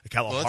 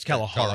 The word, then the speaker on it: Kalahari: I think I butchered that name. Kalahari. – Well, right. Kalahari. – I think it's Kalahari. Kalahari. I said Hira or something.